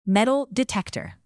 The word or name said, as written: metal detector